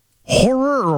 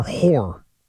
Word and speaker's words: horror: Two syllables or one there? – Pardon me? – Horror or whore? Horror novels? – Horror. – Okay. – Horror. Not fiction about prostitutes.